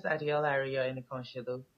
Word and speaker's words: Adeola Ariyo Enikanoselu: So my name is Adeola Ariyo Enikanoselu.